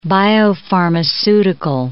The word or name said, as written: biopharmaceutical